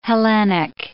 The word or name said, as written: Hellenic